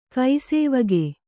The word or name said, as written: ಕೈಸೇವಗೆ